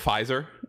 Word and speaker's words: Pfizer: Oh, instead of Pfizer? – What's that word? – Okay, my bad. I understand. – Oh, you guys pronounce the F, weirdos.